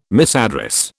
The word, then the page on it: misaddress: British English